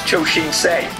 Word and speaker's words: Choshinsei: The subject of the email is a reference to the Japanese Super Sentai show Choshinsai Flashman, pronounced Choshinsei.